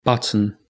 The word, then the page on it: button: British English